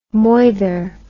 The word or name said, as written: moither